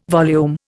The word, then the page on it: volume: Indian